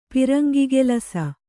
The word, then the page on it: ಪಿರಂಗಿಗೆಲಸ: kannada